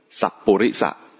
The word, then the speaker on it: สัปปุริสะ: ไม่ใช่สัตบุรุษนะสัปปุริสะสัตบุรุษจะคิดอะไรจะออกเสียงจริงตามบาลีก็สัตบุรุษก็คงออกเสียงยากก็เลยกลายเป็นสัตบุรุษสัปปุริสะปุริสะคือบุ